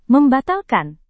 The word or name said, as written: membatalkan